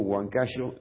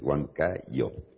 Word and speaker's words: Huancayo: Nosotros en general decimos Huancayo y no es Huancayo. Acá se pronuncia Huancayo.